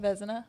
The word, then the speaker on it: Vezina: I saw Igor won the Vezina trophy. Is that how you say that? – Vezina.